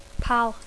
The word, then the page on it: pal: English